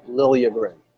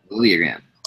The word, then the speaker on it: Liljegren: But like you said, they signed Timothy Lidgren. To a 3-year entry-level contract, but also it's been clarified his name is pronounced Liljegren. – Liljegren.